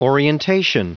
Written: orientation

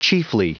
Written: chiefly

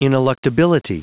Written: ineluctability

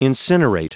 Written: incinerate

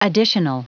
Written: additional

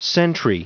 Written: sentry

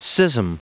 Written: schism

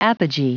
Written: apogee

